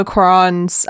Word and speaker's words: Cuarón: Because this is the era where we get to Alfonso Cuarón's reimagining of the Harry Potter series.